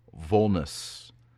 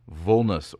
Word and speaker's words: vulnus: Now, the Latin word of the week is vulnus. Vulnus, or if you want to use classical pronunciation, vulnus.